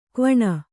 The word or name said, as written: ಕ್ವಣ